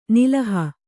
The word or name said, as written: ನೆಲುಹು